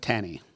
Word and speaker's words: Tani: Tani. – Yes. – Okay. – See, the phonetic pronunciation is a tan. Tani. But the way I say tan don't sound the same.